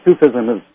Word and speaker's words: Sufism: Well, how do you pronounce that, S-U-F-I-S-M? – Sufism.